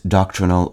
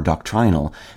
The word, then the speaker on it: doctrinal: Its adjectival form, pronounced doctrinal or doctrinal, meaning of relating or preoccupied with doctrine, as in doctrinal teachings, is straightforward and not particularly judgmental.